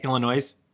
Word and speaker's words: Illinois: Illinois. – No, you dropped the S. It's just a silent S that's there.